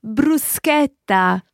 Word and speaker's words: bruschetta: Bruschetta.